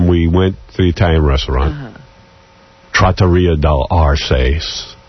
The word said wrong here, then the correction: dell'Arte: I don't know how to say it. Trattoria dell'Arces. – Dell'Arte.